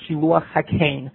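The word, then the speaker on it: Shiluach HaKain (שילוח הקן): The Meloh HaRom in the back of the Gemara brings down that it's called Shiluach HaKain, with a tsere for Kain. Shiluach HaKain. The reason for it being that the correct translation for it would be Kain.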